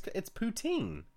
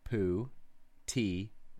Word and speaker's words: poutine: You're pronouncing it wrong. It's poutine. Is it what? How else would you say it? Where's the pronunciation over there? – Pronunciation. – Poo-tee-n.